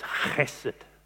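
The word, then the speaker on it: chesed (חסד): You've got to get a really big hoik in your mouth to say it properly. It is chesed.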